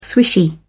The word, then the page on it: swishy: British English